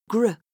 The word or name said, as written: gr